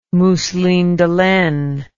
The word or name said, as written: mousseline de laine